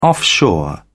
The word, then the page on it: offshore: British English